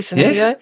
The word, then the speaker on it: Senecio: Am I saying that properly, Senecio? Yes. There are lots.